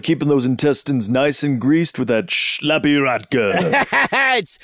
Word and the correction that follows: Slappy: Say it correctly, please. Slappy the Russian brand potato vodka, made with only the coldest vodkas and the dirtiest potatoes.